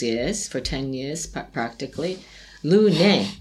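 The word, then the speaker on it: Lunae: Una era una Mazaretta giovanile in assemblaggio con Liguria di Levante IGT, Cantina Lunai. – Lune. In latino non si legge la... – Ah, quindi... – Questo è latino, lune. – Lune. – Lune, devi dire.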